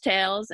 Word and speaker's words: Tayls: And that's spelled like T-A-L-E-S for the first Tales. And then the second Tales is like my name, T-A-Y-L-S.